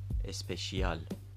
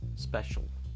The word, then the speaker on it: special: خب پس آنچه ما میگوییم اسپشیال آنچه آنان میگویند special